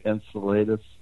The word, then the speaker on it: Enceladus: And I wanted to ask you, if it's what is your opinion on Europa Enceladus? I can never say that right. – Enceladus.